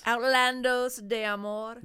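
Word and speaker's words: Outlandos d'Amour: Outlandos de Amor.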